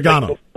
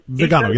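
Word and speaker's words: Viganò: I probably Vigano. – Butcher it. Vigano. – Yeah, Vigano, yes.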